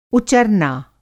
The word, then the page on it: उचरना: Hindi